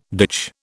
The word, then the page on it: ditch: British English